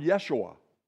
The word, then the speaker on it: Yeshua: It was the word Yeshua. That's how they pronounce it.